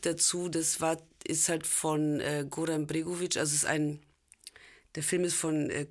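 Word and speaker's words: Goran Bregović: Goran Bregovic, ja.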